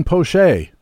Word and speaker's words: Poche: This week's Frequent Flyer is Tampa reliever Colin Poche. That's how it's pronounced.